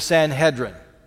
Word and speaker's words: Sanhedrin: Sometime after that, the disciples are hauled in before the high council called the Sanhedrin. Rhymes with Excedrin, just so you know.